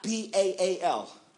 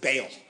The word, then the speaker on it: Baal: B A A L. Some people pronounce it Baal. The correct pronunciation is Baal.